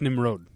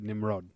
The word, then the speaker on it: Nimrod: I believe it's pronounced Nimrod. – Is it? – Okay. – One of the actors was on Film Spotting this week. – Okay. – And he said the name, and he said Nimrod.